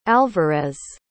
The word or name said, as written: alvarez